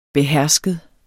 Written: behersket

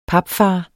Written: papfar

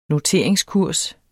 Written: noteringskurs

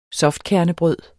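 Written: softkernebrød

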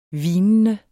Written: hvinende

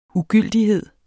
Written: ugyldighed

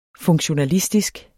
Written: funktionalistisk

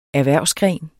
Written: erhvervsgren

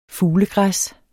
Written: fuglegræs

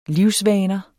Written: livsvaner

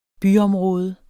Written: byområde

